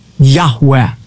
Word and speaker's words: Yahweh: In Hebrew, it would be composed of the four Hebrew letters, Yud, Hey, Vav, Hey, which most Semitic scholars believe is pronounced Abrathi Yahweh.